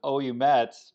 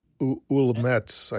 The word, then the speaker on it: Ouilmette: Well, I would always pronounce it Oumettes because it's O-U-I. – It's, yeah, O-U-I-L-M-E-T-T-E. – But in French, you know, that's we, yes. – Wilmettes, I guess.